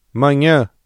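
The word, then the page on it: manhã: Brazilian Portuguese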